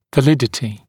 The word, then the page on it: validity: və’lɪdətɪ; вэ’лидэти